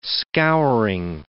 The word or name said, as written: scouring